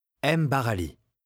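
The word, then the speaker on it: M'Barali: M. Barali.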